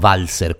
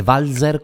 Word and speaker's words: Walser: Normalmente noi diciamo Walser con la S dolce, lei dice Walser con la S sonora, quindi è più giusta la sua, ovviamente.